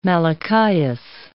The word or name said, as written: Malachias